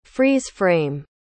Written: freeze-frame

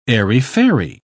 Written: airy-fairy

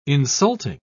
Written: insulting

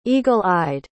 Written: eagle-eyed

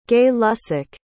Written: gay-lussac

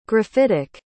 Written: graphitic

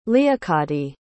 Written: leocadie

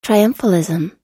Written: triumphalism

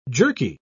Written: jerky

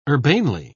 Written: urbanely

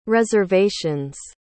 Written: reservations

